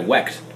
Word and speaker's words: Wecht: You got it, Wecht.